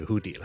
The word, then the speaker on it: hoodie: 再俾多个例子大家，譬如我哋冬天好多时候会着嘅一个连帽嘅卫衣，我哋叫 hoodie 啦。 Hoodie 的确咧，我哋读 u u u 音嘅，咁嗰啲矫枉过正人咧，可能就会将佢读成咧 hoodie。